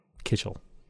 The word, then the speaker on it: kichel: So I'm going to go with Kichel, or Kitchell, K-I-C-H-E-L. Kitchell. – Correct.